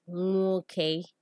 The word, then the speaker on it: nwoke: Next, there is NW, which is pronounced we. An example of a word with the wing consonant is nwoke, which means male in Igbo.